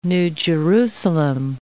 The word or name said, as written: New Jerusalem